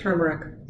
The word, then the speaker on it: turmeric: Well, it has that extra R in it, but right. – Nobody says it. Do you say it? – Turmeric.